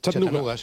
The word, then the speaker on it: Chattanooga: ¿Se pronuncia así en Chattanooga? – Chattanooga. Sí.